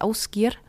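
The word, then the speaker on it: Ásgeir: Oder dessen Musik ich sehr mag, der heißt Ausgir. Das schreibt man wie Aasgeier.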